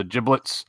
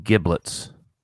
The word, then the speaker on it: giblets: Is it a silent G? We call them giblets. – That's not silent. That would be giblets if it was silent. – You said giblets. – So you're saying a soft G as opposed to a hard G? – Soft G, yeah.